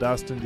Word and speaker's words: Dustin: My last name is spelled, of course, first name is spelled Dustin, D-U-S-T-I-N. Last name is spelled Warnke, W-A-R-N-C-K-E, and that is on my YouTube channel.